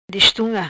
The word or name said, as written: Destunga